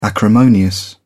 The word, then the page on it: acrimonious: British English